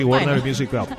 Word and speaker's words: Warner Music Group: la pronunciación, Warner Music Group. ¿Lo dije bien? – Sí, sí, Warner Music Group.